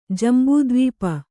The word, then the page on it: ಜಂಬುದ್ವೀಪ: jambu dvīpa